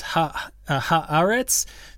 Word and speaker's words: Haaretz: It's Haaretz, three syllables with the accent on the second syllable."